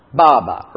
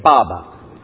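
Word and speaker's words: Baba (爸爸): It's not Abba, it's Baba. Right. And get the accent on the first syllable. Can you say that again? Baba. Okay, that's what they say in Chinese.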